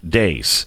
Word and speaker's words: Deace: The Faucian Bargain by Steve Deace. Go there now. By the way, his name is spelled like peace, except with a D, and it's pronounced Deace.